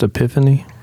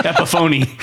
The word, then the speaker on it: Epiphone: Is it not pronounced Epiphany? – It is not a pronounced Epiphany. – Epiphony.